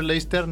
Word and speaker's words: Leicester: que decimos Leicester, pero Leicester, ¿no? Creo que se pronuncia Leicester.